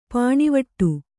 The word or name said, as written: ಪಾಣಿವಟ್ಟು